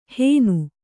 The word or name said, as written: ಹೇನು